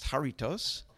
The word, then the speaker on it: Jarritos: I believe it's pronounced Jarritos, and they're thank you.